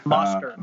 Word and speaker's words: Mostert: He didn't look great, but he did just hand the ball to Mozart. Most Mozart. Uh, sorry, I'm pronouncing his name wrong, but he's a beast. Mostert. Um, there you go.